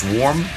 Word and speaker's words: Swarrrm: Is it pronounced Swarm? – Yeah. – And what was this? It's like three M's? – Three W's. – I'm sorry. Swarms. – Oh, shit. I'm sorry. Three R's. S-W-A-R-R-R.